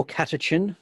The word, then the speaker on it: catechin: Yeah, C A or catechin.